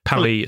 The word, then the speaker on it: Paris: Paris, it's pronounced. Alan Paris. – Right. – He insists on it. – Oh, is he French, is he? Mm-hmm. All right, sorry. Charlie Palmer. – No, he's not French. He just insists on it being pronounced that way.